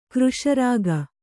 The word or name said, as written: ಕೃಶರಾಗ